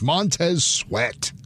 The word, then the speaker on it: Montez Sweat: Yeah, it's a guy you mispronounced earlier. It's Montez Sweat.